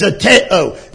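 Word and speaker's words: zeteo (ζητέω): It's zeteo. It is spelled Z-E-T-E-O.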